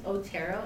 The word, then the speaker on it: Otero: Okay, so my last name is Otero, and it's spelled O-T-E-R-O. – O-T-E-R-O? – Yes.